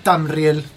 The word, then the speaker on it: Tramiel: ¿Cómo se pronuncia? – Tramiel. – No, es Tramiel.